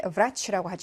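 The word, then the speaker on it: vrach라고 (врач): 지금 하고 있는 이 아이는 윗니로 아래 입술을 스치면서 발음하시면 돼요. ㄹ, ㄹ. 그래서 발음이 완전히 다르다는 거 유념하시고 의사를 vrach라고 하죠.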